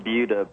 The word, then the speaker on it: Buda: It's spelled B-U-D-A. – I know, but it sounds the same. – It's actually pronounced Buda, but... – Buda. See, there you go. We were messing it up.